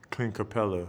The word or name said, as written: Clint Capela